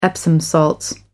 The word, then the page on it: epsom salts: American English